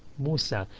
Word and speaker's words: موسى: And that book belongs to Musa. And Isa and Musa both have Alif Maqsura at the end, so you don't pronounce the E sound.